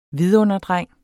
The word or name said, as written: vidunderdreng